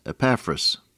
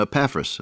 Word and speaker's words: Epaphras: When I was in seminary, Dr. Maxwell insisted that we call him Epaphras, and I've done that all the time since 1938 or 9. In your King James Version, you find the accent on the first syllable, Epaphras. But he used to say, call him Epaphras. Epaphras sounds too much like sassafras.